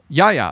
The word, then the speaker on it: Yahya: Not Yahya? – Yahya. Oh, yeah. Yeah, that's how you say it.